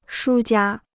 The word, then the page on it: 输家: shū jiā